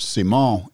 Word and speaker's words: Simon: I've heard all kinds of stuff, but Seymour, it's spelled S-I-M-O-N, but it has an emphasis on this, whatever, a tilde or whatever it's called over the second syllable, Seymour.